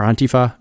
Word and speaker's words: Antifa: The book in question was kind of dealing with the quote-unquote dangers of Antifa, or Antifa, as you should really pronounce it.